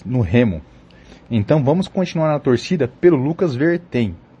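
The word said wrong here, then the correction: Verthein: ou melhor, Lucas Vertain, que foi a pronúncia correta informada ontem durante a transmissão da prova do Lucas.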